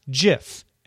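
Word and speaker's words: GIF: It is a soft G, pronounced JIF. End of story."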